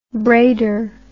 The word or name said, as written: braider